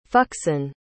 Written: fuxin

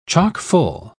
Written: chock-full

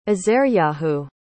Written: azaryahu

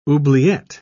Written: oubliette

